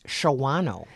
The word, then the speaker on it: Shawano: but it is spelled like it is pronounced Shawano.